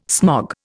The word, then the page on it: smog: Indian